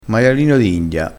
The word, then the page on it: maialino d india: dialettale